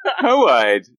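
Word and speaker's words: Hoid: Hoide, the Ska informant. – I just wasn't expecting you to pronounce his name like that. – Well, he's from Elantris originally, so that's how his name is said.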